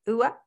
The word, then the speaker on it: Sørevik: It's M-A-T-I-A-S. That's my first name. And then S-O-R-E-V-I-K. – The O with the dash through it, what's that called? – It's in Norwegian, it's called an ø, just like an O with a line over it. – Oh, that's a pronunciation? Ua? – Yeah. – Ua. So it's a I see. So if you didn't pronounce it that way, it would be a normal O.